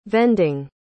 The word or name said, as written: vending